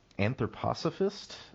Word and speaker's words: anthroposophist: He was an anthroposophist, if that's how you pronounce it. – Yeah, well done. And it took me just to get it right.